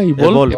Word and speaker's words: Evolve: Evolve.